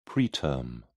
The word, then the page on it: preterm: British English